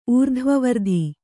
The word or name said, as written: ಊರ್ಧ್ವವರ್ಧಿ